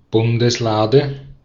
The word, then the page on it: Bundeslade: /ˈbʊndəsˌlaːdə/